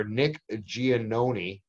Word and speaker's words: Nick Giannone: Nick Giannone.